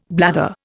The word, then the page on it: blather: Indian